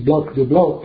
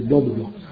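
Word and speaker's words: Blanc de Blancs: You pronounce it Blanc de Blanc, but in French we say Blanc de Blanc.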